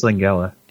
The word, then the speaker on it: Langella: I think it's Langella.